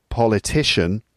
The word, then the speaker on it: politician: But then a person, that the person noun, that's politician. So politician. So the word stress is now on the third syllable.